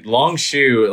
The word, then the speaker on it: Longshore: Yeah, Longshore, like that's flashing me back to my high school days of playing at Eagles Landing where one of my teammates just could not pronounce my name properly, kept leaving the R out, and Longshore stuck.